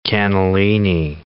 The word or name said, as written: cannellini bean